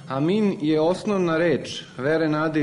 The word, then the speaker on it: amin: To je reč koju mi obično izgovaramo Amin, koju bi pravilnije bilo izgovarati, dakle kako se u originalu izgovara, kao Amin, sa tim naglaskom na posljednjem slogu.